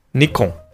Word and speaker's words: Nikon: Nikon. – In English, Nikon. – N-I is pronounced as Nai, but this name comes from Nippon, so this should be pronounced as Ni.